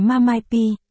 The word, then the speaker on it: MMP: Em không muốn sao, Tạ Tịch, MMP, ngụ bán phê, Mamai-P, phát âm là Mamai-P, viết tắt MMP.